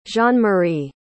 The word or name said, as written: jeanmarie